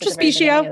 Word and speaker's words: Trespicio: Is that how you say your name? I should have asked. – Trespicio. That's right. That's right.